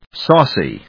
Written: saucy

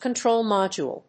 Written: control module

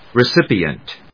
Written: recipient

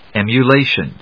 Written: emulation

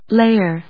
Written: layer

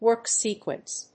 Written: WORK SEQUENCE

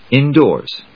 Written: indoors

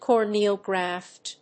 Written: corneal graft

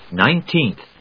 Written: Nineteenth

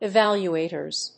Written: evaluators

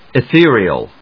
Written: ethereal

